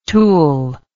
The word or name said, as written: Toul